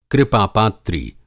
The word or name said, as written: कृपापात्री